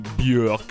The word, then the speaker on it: Björk: Oggi ha vinto Medulla di Björk, che adesso inizierò a fare il figo con questa pronuncia con dieresi sopra la O, ma in realtà ho sempre detto Björk in tutta la mia vita e mi scapperà detto anche in questa puntata.